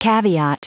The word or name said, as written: caveat